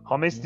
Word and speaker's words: James: Hames işte. Mesela Hames. O nasıl öğrendik bunu? O maçı anlatan yerin diğer meslektaşlarıyla o kadrodaki isimlerin nasıl e, telaffuz edildiği üzerine sohbetler Yok şöyle olmuştu. Hünkar Mutlu, yani. Hünkar Mutlu açıklamıştı onu. Kolombiya'daki elçilikten falan bilgi almışlar galiba. Hatta de 3 kere sordum. James olmasın biz o zaman hep James diyorduk hatırlıyorsun Porto'daki o genç Kolombiyalıya.